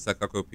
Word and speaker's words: Sakoku: when Japan was closed off during the Sakoku period,